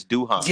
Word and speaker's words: Duhon: You talking about Chris Duhon? – Yeah. – Duhon. Oh yeah, yeah. That's how you pronounce it. Yeah, Duhon. Right. – Not Dunn, Chris Duhon.